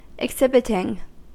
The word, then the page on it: exhibiting: American English